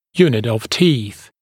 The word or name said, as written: unit of teeth